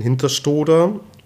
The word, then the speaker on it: Hinterstoder: Wir haben jetzt in Hinterstoder, richtig ausgesprochen? – Mhm, genau. – Danke. Hinterstoder haben wir jetzt einen Riesenslalom, okay.